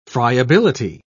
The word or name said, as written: friability